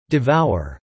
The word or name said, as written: devour